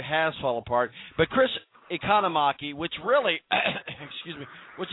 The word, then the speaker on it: Economaki: And unfortunately, we're talking about the passing of Chris Economaki. How do you say the last name? – Economaki. – Economaki. I got it right now.